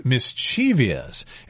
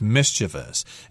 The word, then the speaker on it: mischievous: I should mention that the word mischievous is often mispronounced as mischievous. It's one of those words that is mispronounced so often a lot of people think it's the real word, but technically the correct form is mischievous.